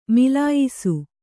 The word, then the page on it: ಮಿಲಾಯಿಸು: milāyisu